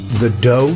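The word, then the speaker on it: The Do: And my featured artist this week is Finnish French pop duo The Doe, spelt Do in English, D-O, but pronounced The Doe.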